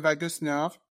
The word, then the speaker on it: vagus nerve: Is it vagus nerve? – Vagus nerve, yeah. – Vagus nerve, so I don't know what that is. And so you said that the vibrational frequency stimulate the vagus nerve, and that's really important for the overall health.